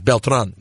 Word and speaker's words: Beltran: Definitely Beltran. About that. Beltran is definitely the proper pronunciation.